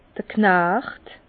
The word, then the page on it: d'r Knacht: Bas-Rhin; Herrlisheim